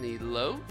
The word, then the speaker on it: elote: And didn't you get how do you say it, an elote? – Elote.